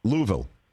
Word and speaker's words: Louisville: How do you say the city in Kentucky where they make bats? – Louisville. – Okay. That's what I say, Louisville. – You have to. It's not Louisville.